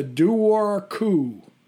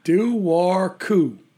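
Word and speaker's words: dobharchú: It's called the Duar Cú. – Duar Cú? – Have you heard of that one? – Exactly. No. – Duar Cú. – Okay. – Which I guess in Gaelic is spelled D-O-B-H-A-R-C-H-U.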